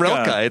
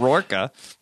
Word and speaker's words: Rilke: Yeah, why is it Rilke? It's not Rourke, right? – Oh, my gosh.